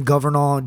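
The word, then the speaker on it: Governale: Just Justin Governall, @JustinGovernall on Instagram. And my name is the same thing on Facebook. – Hell yeah. – Spell that last name. – @Justin, Justin, Governall, G-O-V as in Victor, E-R-N-A-L-E, @JustinGovernall.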